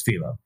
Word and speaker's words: Filho: Oh, Pedro Rodrigues Filho. Okay. – Which I had to look out how to pronounce it because it's spelled F-I-L-H-O.